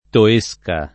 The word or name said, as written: Toesca